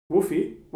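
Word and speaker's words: WUFI: How do you pronounce Woofy? – Woofy? Well, you pronounce it like that and acronym.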